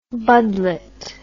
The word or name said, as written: budlet